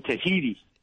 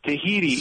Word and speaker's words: Tahiti: Tahiti. – Specific to Tahiti. – Well, it rhymes with sweetie.